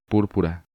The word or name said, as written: Purpura